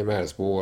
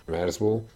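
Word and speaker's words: Merzbow: Merzbo 1979 óta több száz felvételt jelentetett meg. A projekt neve Kultschlitzer Merzbo című alkotásából ered, és a kiejtése is megegyezik azzal.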